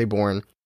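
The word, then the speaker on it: Borgne: I think the G is silent, so I'm going to say born.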